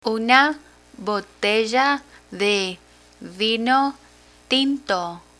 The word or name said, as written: una botella de vino tinto